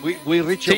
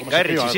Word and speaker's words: Guy Ritchie: Guy Ritchie. – Sí, Guy Ritchie.